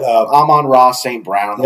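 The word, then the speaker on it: Amon-Ra St. Brown: But Amon Ross St. Brown, wide receiver for the Detroit Lions.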